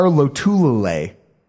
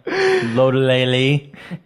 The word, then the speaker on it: Lotulelei: You bring in Panthers, former Panther star Lotulelei. That's right. That's the first time I said it correctly because I'm sick of trying to piss off a bunch of people who... – Mario. Lotulelei.